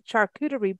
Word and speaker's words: charcuterie: And also the last month in September, we talked about pairing wines with charcuterie boards. Did I say that right, Peggy? Charcuterie? – Oh, yes, you did.